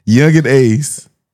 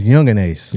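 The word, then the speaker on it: Yungeen Ace: Oh, it's Young and Ace. – Yeah, not Young and Ace. – Young and Ace. Okay. – Yeah, you spell it Y-U-N-G-E-E-N.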